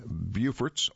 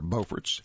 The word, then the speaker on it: Beaufort: The name is spelled, but the same, but pronounced differently, depending on whether you are in North or South Carolina. Writers Seeley and Lynn Selden's article, Battling Beauforts or Beauforts, explains how these two coastal communities share more than a name.